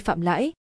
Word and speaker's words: Phạm Lãi: Hai chữ, lãi có hai cách phát âm là L, Thanh Ba và Lý, Thanh Hai. Tên của Phạm Lãi là Thanh Ba.